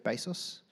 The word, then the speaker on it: Bezos: Jeff Bezos. Is that how you say it? Jeff Bezos? My brother's nodding.